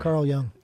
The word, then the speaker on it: Carl Jung: I believe it was the philosopher Carl Jung. I hope I'm saying that right. – Carl Jung. – Yeah. Carl Jung.